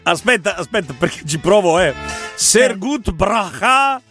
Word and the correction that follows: brav: Brav. – Ah, brah, ho capito. – Brav. – Con l'H finale.